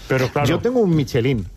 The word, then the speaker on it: Michelin: A ver, aquí en la Península Ibérica hacemos Michelin. – Sí, claro, pero la realidad, tú eres un chef internacional. – Ya, pero ¿tú cómo lo pronuncias? – Me cuesta ser tan tontorraco, ¿no? Dice Michelin. – ¿Michelin? – Yo digo Michelin.